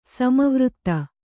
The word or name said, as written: ಸಮವೃತ್ತ